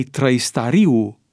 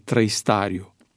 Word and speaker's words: Trăistariu: Mihai Trăistariu în loc de Mihai Trăistariu. Apropo de numele lui Mihai Trăistariu, mă uit cu uimire că Wikipedia, în limba engleză, indică pronunția greșită, Trăistariu, cu accentul ca în cazul numelor grecești.